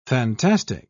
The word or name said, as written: fantastic